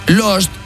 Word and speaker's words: lust: el garito divierte, inspira y educa, porque vamos a ver, last, L-A-S-T, es... – Último. – Lost, L-O-S-T, es... – Perdido. – Y lust, que no se pronuncia lust, sino que se dice también lost, pero... – Last. – L-U-S-T significa...